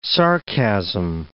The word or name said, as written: sarcasm